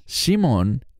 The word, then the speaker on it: Shimon: Estoy consciente de que en español se dice Simeón, pero literalmente, en el idioma original, la pronunciación correcta, debido a que todos eran judíos, sería Simón. Y esa palabra, Shimon, ese nombre, tiene que ver con oír y responder.